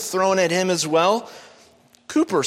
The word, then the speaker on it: Cowper: Now, William Cooper, some say William Cowper, but it's pronounced William Cooper.